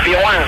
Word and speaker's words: Pizjuán: No, a ver, vamos a pronunciarlo bien. Pizjuán.